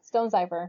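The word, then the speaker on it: Stonecipher: I don't know how you spell it, but J. Benton Stone Sipper? – Cipher, yeah. – Stone Cipher.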